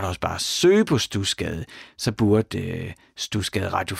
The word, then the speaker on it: Studsgade: så skal du bare lige huske det der med, at når jeg siger stusgade, så staves det S-T-U-D-S, altså sådan studsgade. Men man udtaler det altså stusgade. Lidt ligesom det hedder ikke Bredstrup, det hedder Brastrup. Jeg kan ikke sige hvorfor, det hedder bare Stusgade. S-T-U-D-S-G-A-D-E, snabelag radio4.dk, og så lander din e-mail lige i min inbox.